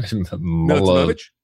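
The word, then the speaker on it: Milutinović: Milutinovic.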